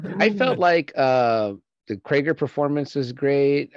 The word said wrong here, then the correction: Kraglin: Did I say it right? Kraglin.